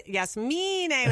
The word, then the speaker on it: Yasmine: It's like, it's kind of like that thing we were saying about how you pronounce my name, Yasmine. – Yeah. Yeah. – It's the Yasmine with the emphasis on the me.